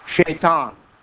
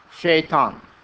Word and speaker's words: Shaitan: And it's the same word, but we don't pronounce the word with S. We pronounce it with S-H. Shaitan. Shaitan.